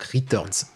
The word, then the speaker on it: Riteurnz: mais ça s'écrit R-I-T-E-U-R-N-Z, donc Returns, vraiment.